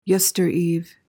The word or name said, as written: yestereve